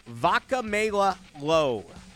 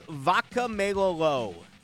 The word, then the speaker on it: Vakameilalo: They signed Kalani Vakamela-Lowe. Kalani Vakamela-Lowe. It is spelt V-A-K-A-M-E-I-L-A-L-O.